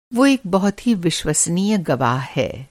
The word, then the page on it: विश्वसनीय: Hindi